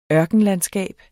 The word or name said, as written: ørkenlandskab